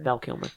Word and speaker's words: Val Kilmer: Is that how you say his name? – Val Kilmer.